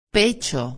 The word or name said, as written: pecho